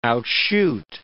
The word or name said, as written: outshoot